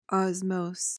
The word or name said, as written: osmose